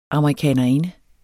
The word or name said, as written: amerikanerinde